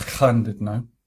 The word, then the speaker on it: Llandudno: I went to Conway, Llandudno. – I think you'll find that's pronounced Llandudno. – Right. – Two L's is in Welsh.